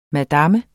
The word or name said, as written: madam